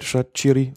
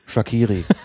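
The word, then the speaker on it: Shaqiri: Ich war so dankbar, dass ich ihn endlich richtig aussprechen kann. Rede ich mit Schweizern und was sagen die? Shakiri.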